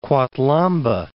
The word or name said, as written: Kwathlamba